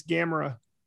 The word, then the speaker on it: Gamera: I think it's Gamera. It's Gamera. – Gamera.